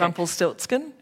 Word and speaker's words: Rumpelstiltskin: Rumpelstiltskin? – Okay, that's the one.